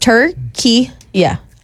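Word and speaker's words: Türkiye: R-K-I-Y-E, and I don't even know how the official pronunciation is. Is it still Turkey? I don't know. – Pronounced Turkey, yeah.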